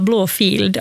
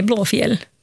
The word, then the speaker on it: Blåfjäll: Blå, det som folk brukar tendera att uttala Blåfield, alltså blå, alltså en F-I-E-L-D. Ja. För att det är en gammal stavning av ordet fjäll. Så det namnet är Blåfjäll.